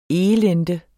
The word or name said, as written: elende